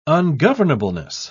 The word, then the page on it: ungovernableness: British English